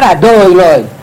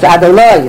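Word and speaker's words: Adonai: You're going to put the accent rather on the wrong syllable. So you're going to say Adoi, Loi. That's wrong. It's not Adoi, Loi. It's Adoloi, it's on the loy part of the word.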